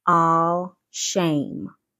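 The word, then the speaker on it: ashem: In the Old Testament dictionary, 818 is a shim, A-H, well, I know I said that wrong, A-H, A-S-H-E-M, and it's pronounced all shame.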